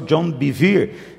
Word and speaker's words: John Bevere: o pastor John Bevere. Essa é a pronúncia correta, mas você pode chamar ele de João Bevere também, se você quiser.